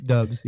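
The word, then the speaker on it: Doubs: For me, I'm more looking at guys like Romeo Dubois. I don't know how to say his name. – Dubs, yeah. – Romeo Dubs.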